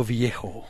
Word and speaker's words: Viejo: Barrio Viejo. – Barrio Viejo. It's actually, you say it more like a V sound, like Barrio.